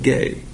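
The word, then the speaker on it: gay (γῆ): The Greek word, and I've got work there, so there's an error right there, is spelled G-E, but it's pronounced gay.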